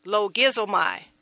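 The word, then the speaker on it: logizomai (λογίζομαι): And the pronunciation should be logizomai. Logizomai. So that would be logizomai.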